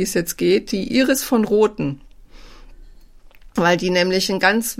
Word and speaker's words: von Roten: In der Schweiz wird es eigentlich von Roten ausgesprochen, also wird es hm. von betont.